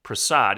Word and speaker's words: Prasad: It's P-R-A-S-A-D, Prasad.